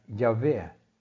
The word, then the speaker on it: Javé: Det hebraiske ordet for Herren er Javé, eller tetragrammet, som vi kaller det. Altså fire bokstaver J, H, WH, som vi vanligvis uttaler Javé.